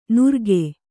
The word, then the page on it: ನುರ್ಗೆ: nurge